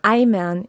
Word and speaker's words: Eimern: Eimern, yeah. Eimer is spelled E-I-M-E-R.